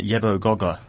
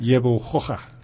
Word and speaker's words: Yebo Gogga: Yebo Gogga. You had it much better than that. You say it again. – Yebo Gogga. – Yes, that.